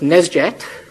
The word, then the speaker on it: nsjt: you come up with this word, NSJT, pronounced Nesjet, which means epilepsy in ancient Greek.